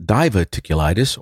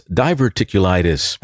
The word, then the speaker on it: diverticulitis: It's not diverticulitis or divoticulitis. It's diverticulitis.